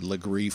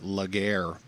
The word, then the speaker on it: Legare: It's a very, very old Charleston name, Legree, spelled L-E-G-A-R-E. And if you're new to the area, most people pronounce it Laguerre, but that would be a tip-off that you have moved here and are not haven't been here a long time.